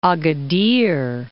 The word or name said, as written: Agadir